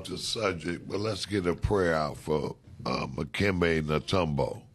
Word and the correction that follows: Dikembe Mutombo: First of all, say his name right. His name is Dikembe Mutombo.